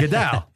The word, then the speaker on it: Goodell: Let's go to this Goodell. That's how you say it. Goodell.